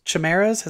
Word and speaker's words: Chimeras: Chimeras. Is that how you say it? – Yeah.